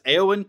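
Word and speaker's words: Eoin: I did learn enough that, and I found it very interesting, the author, and I had to learn, have him say it in order to learn how to say it. So it's Eowyn Colfer.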